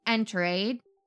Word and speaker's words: Enterade: Enterade kind of sounds like Gatorade, but it's definitely not the same thing.